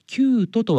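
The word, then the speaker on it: 宮都: この飛鳥藤原のキュート、キュートとは漢字で宮に都と書きますが、世界遺産の決定ニュースの際、私が AI に読み方を聞くと、飛鳥藤原の宮戸と教えてくれました。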